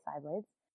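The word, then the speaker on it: sai blades: Cyblades, S-A-I, is that how you pronounce it? – S-A-I, yeah, that's right, Cyblades.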